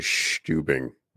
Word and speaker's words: Stubing: I think we're pronouncing his name wrong. His name is Stubing.